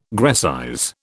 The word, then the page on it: Grecize: British English